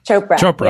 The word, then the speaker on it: Chopra: Chopra.